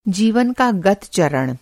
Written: गत

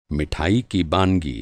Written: बानगी

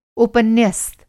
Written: उपन्यस्त

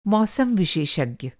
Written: मौसम विशेषज्ञ

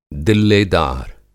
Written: दिल्लेदार

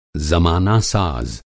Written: ज़माना-साज़

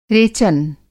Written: रेचन

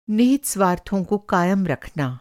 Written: निहित स्वार्थ

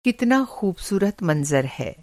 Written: मंज़र

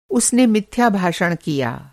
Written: मिथ्याभाषी

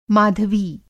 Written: माधवी

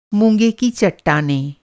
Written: मूँगा

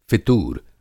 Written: फ़ितूर

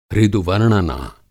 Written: हृदूवर्णना

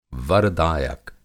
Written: वर-दायक

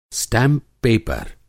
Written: स्टैंप-पेपर

